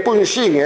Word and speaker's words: Punxín: Cando isto ocorra, cando os galegos aprendan que as xotas de Irixo e de Bixueses e os xes de Punxín e de Sanxenxo se pronuncian como as CH francesas, teremos dado un grande paso na verdadeira pronunciación.